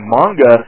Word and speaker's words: manga: But one thing that really annoys me is on some other anime-related programs, on TV or more likely the internet, when they do not pronounce manga as manga, they pronounce it as manga. It is not manga, because that is not how Japanese is pronounced. It's manga, okay? Manga, all right? Not manga. So get it straight, pronounce it right.